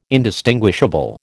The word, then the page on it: indistinguishable: American English